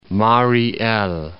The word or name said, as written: Mariy El